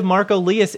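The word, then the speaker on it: Marko Liias: Representative Marco Lias is currently serving in his third term in the Washington State Legislature from the 21st District in South Snohomish County.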